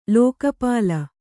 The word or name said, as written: ಲೋಕಪಾಲ